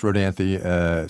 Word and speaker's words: Rodanthe: First of all, it's Rodanthe, so I appreciate that correction.